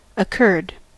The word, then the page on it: occurred: American English